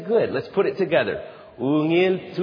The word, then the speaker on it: ungil: So the word is spelled U-N-G-I-L. That's good. U-N-G-I-L, you say ungil.